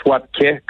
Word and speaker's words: Wapke: Écoute, euh, Wapke, est-ce que je le prononce bien premièrement? – En fait, on prononce Wapke comme un euh, accent grave.